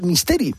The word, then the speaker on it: Misteri: Porque Juan Carlos, a ver si lo digo bien, es nada menos que cantor y miembro de la Capilla del Misteri.